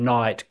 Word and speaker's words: Knight: I have a group, Krypton Knight, spelt with a K, Krypton, KYRPTONE, and then Knight, KNIGHT, so my full surname.